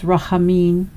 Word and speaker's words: Rachamin: The sound uh, in the Hebrew, rachamin, is uh, pronounced similar to the word jalapeno, so it's rachamin uh, it's a noun, but it can also be used as a verb, as it often happens in prayer.